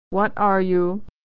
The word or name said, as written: what are you